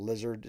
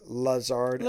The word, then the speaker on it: Lazard: You got this Lizard guy that people would call Lizard. It's really Lazard.